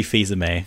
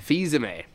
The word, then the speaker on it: Fils-Aime: Is that how you pronounce his name, by the way? That's one thing I've always... Sorry? – It's Reggie Fils-Aime. – Fils-Aime. Thank you. I've always felt like I got that one wrong.